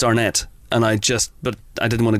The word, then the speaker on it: Arnett: I realised that I said Will Arnett.